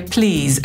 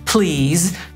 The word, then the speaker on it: please: Po pierwsze, Brytyjczyk powie please, a Amerykanin please, trochę inaczej brzmi litera L. No i wreszcie ten wyraz kończy się w wymowie na z, a nie na s.